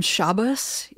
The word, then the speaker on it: Shabbos: Shabbos is Ashkenazi and Shabbat is the other way around. Ashkenazi and Shabbat is Sephardic.